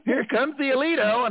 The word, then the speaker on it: Aledo: With this episode is when the ship that they're on is right, the Aledo, right? A-L-E-D-O. – Yeah. – But I kept hearing it being pronounced Alito, right?